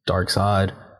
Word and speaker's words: Darkseid: It's kind of in the same way as everything else, where it's an abstraction of Darkseid, because it's not spelled like Darkseid. It is just Darkseid.